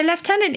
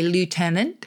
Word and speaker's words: lieutenant: So lieutenant is just nice because I think from the pronunciation point of view, because we use the F and I think British speakers probably think that we are somehow then superior in our pronunciation to the Americans who say lieutenant. But actually lieutenant is closer to the etymology.